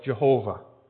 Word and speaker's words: Jehovah: In Hebrew, it is spelled YHWH and pronounced as individual letters. Yod, He, Wah, He. Later, translators added vowels and changed some of the letters to pronounce it as Yahweh or as Jehovah.